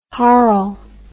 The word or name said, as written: harl